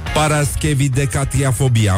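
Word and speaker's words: paraschevidecatriafobia: Paraschevidecatiafobia. Am pronunțat corect? Bravo, mulțumesc! Bravo, Răzvan Exarhu.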